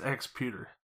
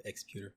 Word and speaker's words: Exputer: I think it's Exputer. – Yeah, like computer, Exputer.